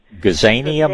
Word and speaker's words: Gazania: I think it's a gaznia. Is that correct pronunciation? – Gazania, maybe? – Yeah, probably so, yeah.